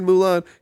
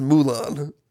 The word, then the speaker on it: Mulan: Tom Bancroft, who created Mulan, he says Mulan. – Mulan. – Like, it bothers me. He puts the wrong... – Fabulous Mula. – Wrong emphasis on the wrong syllable.